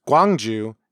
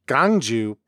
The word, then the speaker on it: Gwangju: The biggest city in South Jeolla province, Gwangju, is often pronounced gangju by Jeolla speakers.